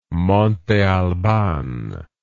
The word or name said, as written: Monte Albán